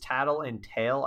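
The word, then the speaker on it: Tatl and Tael: It's Tattle and Tail, I'm assuming is how it's pronounced. Yep.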